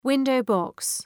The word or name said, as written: window-box